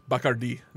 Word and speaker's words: Bacardi: So it's how do you pronounce Bacardi? – Bacardi. The accent is on the I at the end.